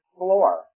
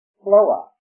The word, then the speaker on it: floor: And it can all be, really described by how they pronounce the word floor. F-L-O-O-R. In the southern part of Georgia, it's pronounced Floa. Floa. No R in the word.